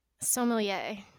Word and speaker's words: sommelier: Sommelier.